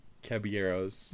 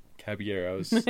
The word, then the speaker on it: Caballeros: Caballeros. – There you go. – Caballeros. Spanish. You don't pronounce the L's.